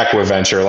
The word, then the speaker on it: AquiVenture: I also wanted to ask you about, sounds like you're involved in another venture called AquiVenture. Am I saying that correctly? – Yeah. Yeah. AquiVenture, like acquisition and venture, AquiVenture.